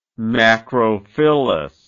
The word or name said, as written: macrophyllous